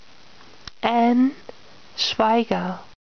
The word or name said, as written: Ann Schweiger